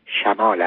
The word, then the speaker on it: شمال: میگویند جنوب غلط است زیرا این واژه جمع جنب به معنی پهلوست و اگر مقصودمان واژه‌ای است که در مقابل شمال به کار می‌رود باید جنوب یعنی به فتح جیم تلفظ شود. از قضا شمال هم غلط است و تلفظ صحیح آن شمال است.